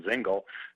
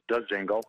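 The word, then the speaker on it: Dzingel: There aren't many other than, you know, if you were to look at Ryan DeZingle, some people have assumed that the D is silent and they call him Zingle. I've heard that, but it is DeZingle.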